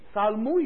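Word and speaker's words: ṣalmu: Salmu is spelled T-S-A-L-M-U.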